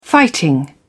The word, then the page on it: fighting: British English